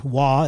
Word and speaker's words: Roy: Perhaps the best goaltender in history, Patrick Roy, or Waugh is the correct pronunciation, was a firm believer in the power of superstition.